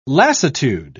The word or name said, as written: lassitude